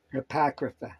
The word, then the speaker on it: Apocrypha: How do you say that, hon? – Apocrypha.